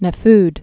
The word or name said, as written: Nefud